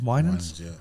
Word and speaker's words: Winans: Winans? – Yeah.